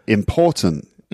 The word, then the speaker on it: important: But important. – Mm. – So in English, the stress is on the second syllable. – Not important, but important.